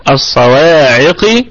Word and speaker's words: الصواعق: الصواعق، الواو فيها نطق طبيعي وصلا ووقفا، الصوا و بعدها ألف. الصواعق بقية الكلمة عندنا الصاد والقاف مفخمتين، لكن القاف ستكون وصلا في أقل درجات التفخيم، ووقفا أيضا لأنها ستكون ساكنة وقبلها مكسور، فأيضا ستكون في أقل درجات التفخيم.